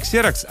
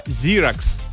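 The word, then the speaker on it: Xerox: По идее, кстати, правильно говорить не ксерокс, а зирокс. Зирокс, правильно.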